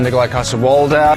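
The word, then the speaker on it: Nikolaj Coster-Waldau: Soy Nikolai Koster waldau Nikolai Koster waldau Coster waldau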